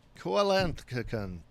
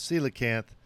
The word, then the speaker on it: coelacanth: Next topic, the coelacanth, which is not pronounced coelacanth, as I have always wanted to say it. The coelacanth, that is coelacanth, is an ancient fish that was thought to be extinct forever.